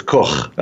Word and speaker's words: Koch: You probably pronounce it Koch. – Koch, yes. Just like the It's a German name, so it's Koch.